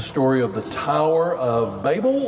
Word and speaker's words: Babel: But I'm going to try to remember to say Babel, because I think that's the better pronunciation.